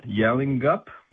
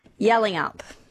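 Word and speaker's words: Yallingup: So a bit windy today, like particularly on the coast if you're in Bunbury, Busselton, or, and tell me if I'm saying this right, Yalling Up? – Yelling up. – Yelling Up, there you go.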